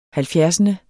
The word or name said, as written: halvfjerdsende